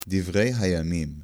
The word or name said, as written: miflaga